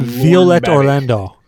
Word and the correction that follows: Orlandi: Violet Orlandi.